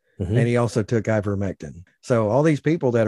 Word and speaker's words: ivermectin: He took ivermectin. I don't know if I'm saying that right. – Ivermectin. – Close enough. – I think there's an N on the end there. Ivermectin.